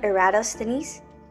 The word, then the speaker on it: Eratosthenes: But have you ever heard of Eratosthenes? I know his name is pretty hard to pronounce, but I want y'all to say it with me. So, Eratosthenes. So I guess it has like five syllables, but did you get it?